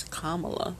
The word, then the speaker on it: Kamala: For some reason, I want to pronounce it Kamala because I went to school with a girl named Kamala, and it was spelled that way, and so that's how I automatically announce it when I see it, but she pronounces it as Kamala.